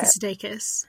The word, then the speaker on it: Sudeikis: Yeah, Jason Sudeikis.